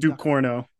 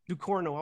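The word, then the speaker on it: Ducournau: Yeah, Raw, but how do you say her last name? – Ducorno. – Ducorno. I always fuck her name up. – Everyone who's American does.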